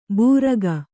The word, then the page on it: ಭೂರ್ಗ: bhūrga